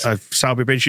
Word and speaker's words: Sowerby Bridge: And then it was a long old trek into Halifax after that, via, how do you pronounce it exactly? The locals would have you pronounce it? – Sowerby Bridge. – Sowerby Bridge, that's it. – Sowerby Bridge is how it looks to be written down, but it's, you've got to know these things.